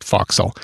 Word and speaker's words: forecastle: Spelled forecastle, by the way, but it's pronounced foxel.